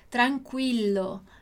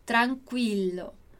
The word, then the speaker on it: tranquillo: A gente também tem a palavra tranquilo no italiano, só que ela tem uma pronúncia um pouquinho diferente, porque tem dois L's. Então, no italiano, tranquilo se diz tranquillo, tranquillo.